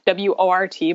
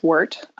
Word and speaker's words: wort: So at first, that sugary liquid is called wort, W O R T, but it's pronounced wort.